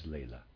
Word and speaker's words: Laila: Readers aside, I'm pronouncing L-A-I-L-A as Layla.